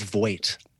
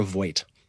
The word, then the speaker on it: Voigt: I think she says Voigt. I'm sure of it, because I've listened to her be introduced at the Met many times. Yes, it's Deborah Voigt. – Yeah, Deborah Voigt.